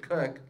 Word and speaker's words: Koch: Like, my last name is spelled K-O-C-H, and we pronounce it Cook. Like, that's ridiculous. Quicksand is slow.